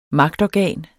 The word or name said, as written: magtorgan